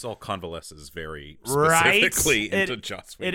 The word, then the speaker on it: Joss Whedon: I'm sorry, it's pronounced Josh Whedon. – Is it? – No. – I was about to say, I'm like, how am I constantly... fucking pronunciation. Joss Whedon.